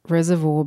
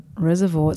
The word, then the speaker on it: reservoir: I believe that it's reservoir because the people that live here call it reservoir.